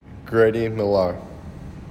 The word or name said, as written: grady millar